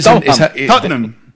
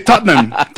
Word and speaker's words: Tottenham: Enséñanos, porque es que es Tottenham. – Tottenham. – Son dos sílabas. – Es difícil, ¿no? – Nosotros los británicos. – ¿Solo dos sílabas? – Sí, Tottenham. Tottenham.